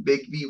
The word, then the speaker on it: Bigby: the name Bigby Wolf, it's spelled B I G B Y, but it's actually Big B Wolf for Big Bad Wolf.